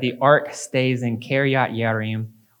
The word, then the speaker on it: Yearim: And it's returned to a city called Kiryat Yearim, which means a city of forests. And it's, ironically, another J word that is pronounced with a Y, Yearim.